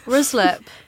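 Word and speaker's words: Ruislip: I was feeling a bit existential this morning because I got on the tube trying to figure out how the fuck you pronounce West Ryslip. – Ryslip. – Is that how you say it? – Ryslip.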